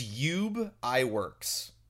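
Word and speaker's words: Ub Iwerks: I believe it's Ube Iwerks is how it's pronounced.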